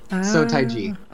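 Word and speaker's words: Seo Taiji: Help me, but I always like mess up. Taiji. – Yeah. So Taiji. – Okay, Taiji. Okay.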